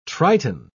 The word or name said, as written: triton